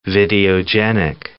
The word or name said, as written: videogenic